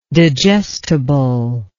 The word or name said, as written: digestibly